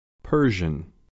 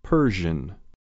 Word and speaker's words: Persian: Persian per Persian Persian خوب دقت کنید پر نباید بگید یه صدای بین او و ا به تلفظ آخر کلمه هم دقت کنید گوش و تکرار Persian